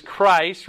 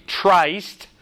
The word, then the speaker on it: Christ: You should know that is Christ. Remember, we don't say Trist. We say Christ.